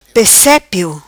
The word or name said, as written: Pesepio